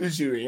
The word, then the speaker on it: Uzui: So the Sound Hashira, I think you said his name's Usui, U-S-U-I, I think is how it's said. – It is Tengen Uzui, yeah. – Is it with an S or Uzui?